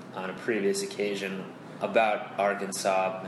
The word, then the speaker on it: Arkansas: it had been my theory that is the state named Arkansas, but it's spelled like Arkansas.